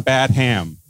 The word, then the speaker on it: Badham: Why are you mispronouncing his name? His name is John Badham. That is the name that's there. – Oh, and Christmas Dinner Spoils. It's Badham.